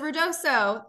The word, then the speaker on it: Ruidoso: And correct my pronunciation, but Ruidoso? – You did it right. Well, it's Ruidoso.